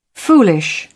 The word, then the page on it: foolish: British English